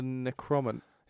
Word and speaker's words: Necronomicon: Necronomicon? – The Necronomicon.